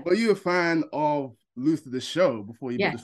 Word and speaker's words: Luther: You are one of the stars of Luther, the Fallen Son, or should I say Lufa? This is the most important question I'm going to ask you today. How are you pronouncing it? Are you doing this on set? Is it Lufa? Is it Luther? – Odette says Luther.